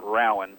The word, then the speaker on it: Rowan: And then once the Rowan County Clerk, and in Kentucky we pronounce it Rowan.